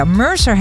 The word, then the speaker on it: Mercer: Casually Complicated van Mercer. Zeg ik dat goed? Ja, Mercer Henderson.